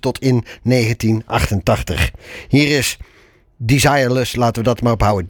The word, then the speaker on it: Desireless: Waarvan ik me afvraag of het niet moet uitspreken als het Engelse desireless. Of meer het Frans klinkende Desiree Less. – Ik dacht desireless was het de bedoeling. Maar op zijn Engels.